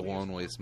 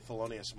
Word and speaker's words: Thelonious: Who did Tabitha Soren deny referring to as the loneliest monk? – Because she's like misreading how to say Thelonious Monk.